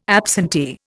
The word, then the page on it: absentee: Indian